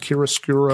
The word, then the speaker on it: chiaroscuro: But he he goes for that dark lighting, and he goes for that How, how do you say it in Italian? Chiaroscuro. – Chiaroscuro. Yeah. I never know how to pronounce it.